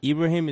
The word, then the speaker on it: Ibrahim: Breezy, so Ibrahim is my name. So it's pronounced with the E, but it's spelled with the I.